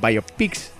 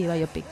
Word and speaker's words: biopic: Docs o biopics. – ¿Cómo se dice? Sí, biopic. – Biopic. – Biopic. – Como puzzle, biopic. La biopic.